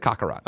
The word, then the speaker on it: Kakarot: I said Kakarot.